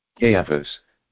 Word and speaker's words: Caiaphas: Caiaphas.